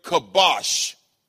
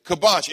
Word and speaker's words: kabash: That word subdue is the word kabash. That is spelled K-A-B-A-S-H. K-A-B-A-S-H, kibosh.